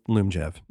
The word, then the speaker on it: Lyumjev: It's L Lyumjev. That's how you pronounce it.